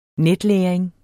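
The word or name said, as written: NETLÆRING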